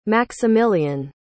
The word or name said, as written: maximilian